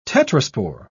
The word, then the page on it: tetraspore: British English